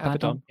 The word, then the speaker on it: Abaddon: Lots of tasty matches to be had between Shida, Baker, Ty, Conti, Nyla, Abaddon. Did I say that right? – Yeah. Abaddon. Yeah.